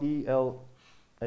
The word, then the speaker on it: Elah: It's called the valley of Ila. Ça s'appelle la vallée de Ela. And in English it's spelled E L A H, Ela.